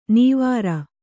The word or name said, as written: ನವಿರು